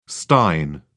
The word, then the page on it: stein: British English